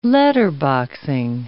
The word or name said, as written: letterboxing